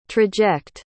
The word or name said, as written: traject